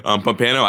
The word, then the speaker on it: Pompano: Pano Beach? – Pompano. I think he played for South Florida Express.